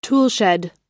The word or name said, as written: toolshed